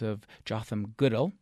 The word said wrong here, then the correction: Goodell: Did I pronounce that right? – Goodell. – Goodell.